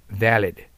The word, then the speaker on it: valid: V は下唇を上で跳ね噛んでください。 valid。